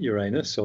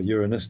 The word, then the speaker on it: Uranus: That was not just about the planet Uranus or Uranus, depending on how you want to pronounce it. – Yeah, the safe way or the dangerous way.